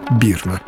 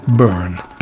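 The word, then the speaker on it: Byrne: а также продюсера Дэвида Бирна. Произношу Бирна, как вот уже 30 лет произносит у нас, хотя в самой Америке говорят все-таки Burn.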